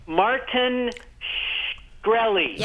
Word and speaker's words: Shkreli: Martin Shkreli.